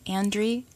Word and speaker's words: Andry: Okay, Whittemeyer is W-E-D-E-M-E-Y-E-R, and Andry is A-N-D-R-Y.